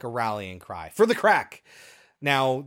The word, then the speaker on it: craic: And it's spelled in the subtitle C-R-A-I-C. That's not actually how it was originally spelled. That's an Irishification. A Gaelic. Or whatever of the word. It's actually just crack. C-R-A-C-K is the origin of the word.